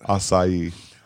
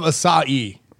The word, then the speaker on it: Asahi: Asahi. – I love Asahi. – Closest. That was the closest. – Asahi. – Take two. – Okay. What I'm trying to... Asahi? – Asahi. – Asahi. – Three syllables.